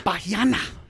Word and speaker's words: Bahiana: and that was by Anna Maria Bahiana. – Bahiana. – Bahiana. B-A-H-I-A-N-A.